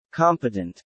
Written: competent

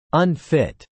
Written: unfit

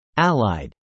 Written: allied

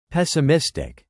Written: pessimistic

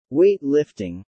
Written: weight-lifting

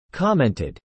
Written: commented

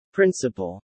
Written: principle

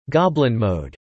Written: goblin mode

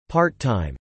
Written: part-time

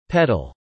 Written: peddle

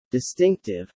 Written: distinctive

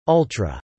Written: ultra

